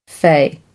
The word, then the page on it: fey: American English